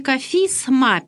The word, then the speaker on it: кафизма: В церковнославянском языке в слове «кафизма» пишется буква «С» – слово, а читаем мы звонко «кафизма» и по-церковнославянски – числительное 20-я, а по-русски 20-я кафизма.